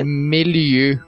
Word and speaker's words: Méliès: É, Méliès. Méliès fazer o biquinho.